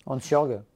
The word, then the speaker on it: Hansjörg: Et aujourd'hui, quand on, quand on regarde les potentiels repreneurs, donc on a euh, un homme d'affaires suisse, Hansjörg ou Wiss, alors je ne sais pas comment on prononce. – Hansjörg.